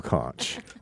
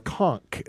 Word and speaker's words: conch: That is a beautiful conch. Or conch, as they say. – Oh, is it a conch? I really don't know. – That's how they pronounce it, like in Key West and stuff.